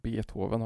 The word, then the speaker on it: Beethoven: Problemet är att dessa namn uttalas med ett kort e i början och att Beethoven har ett långt.